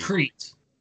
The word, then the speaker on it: Crete: You said it's called like Create or Crete. Crete. Okay. – But spelled like that, yeah.